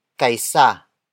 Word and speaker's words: kaysa: The next word is kaisa. Kaisa. Kaisa. Kaisa is spelled as K-A-Y-S-A.